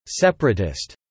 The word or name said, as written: separatist